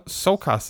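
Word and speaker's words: Csokas: Martin Sokas, or Morton Sokas, is it? – Yeah, the silent C.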